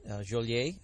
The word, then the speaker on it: Joliet: Actually, its presence on the Des Plaines River and Louis Joliet, Joliet, I believe is the proper pronunciation, was here at a very early stage and helped settle the community.